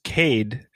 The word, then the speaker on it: Cayde: His name is Cade, and it's spelled C-A-Y-D-E, and then it's Cade -6.